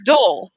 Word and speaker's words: Doyle: And Dole is spelled D-O-Y-L-E. And if you're from this, you know, that part of Tennessee, you don't say Doyle. It's just one syllable. It's dull.